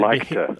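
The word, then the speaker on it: liketa: So this is called counterfactual likta.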